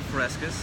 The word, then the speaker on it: Fresquez: Dave Fresquez.